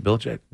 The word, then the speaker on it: Belichick: That's how you say it, Belichick.